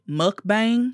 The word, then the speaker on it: mukbang: I think you said it correctly. I say mukbang. – Yeah, mukbang. – But I do that on purpose. – Right. Mukbang, mukbang. I guess if you're from America, it's mukbang.